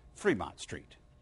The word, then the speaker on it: Fremont: though the locals pronounce it Fremont Street.